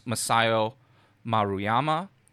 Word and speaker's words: Masao Maruyama: This includes Masao Maruyama, Osamu Dezaki, Rintaro, which is a pseudonym for Shigeyuki Hayashi, and also Yoshiaki Kawajiri.